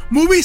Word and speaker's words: Mubi: Movie se escribe M-U, B larga y latina. Sí. Es como decir movie no escrito en inglés.